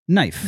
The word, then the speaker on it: knife: The silent K is crazy. Knife.